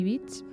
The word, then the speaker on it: Beach: Pues vamos a empezar con una romanza para violín y piano de una autora que se llama Amy Beach, mm. y bueno, la escuchamos, ¿te parece? vamos allá. – Amy Beach. Beach, como si fuera playa.